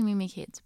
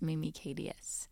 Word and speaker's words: Mimi KDS: I like saying Mimi Kids, but I think the pronunciation is Mimi KDS.